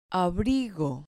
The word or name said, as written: abrigo